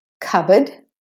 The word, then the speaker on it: cupboard: Cupboard, so the P is silent there.